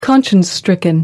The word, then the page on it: conscience stricken: American English